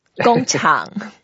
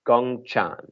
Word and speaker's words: 工厂: 工厂. – 工厂.